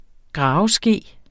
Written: graveske

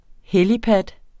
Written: helipad

